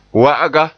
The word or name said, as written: wa'aga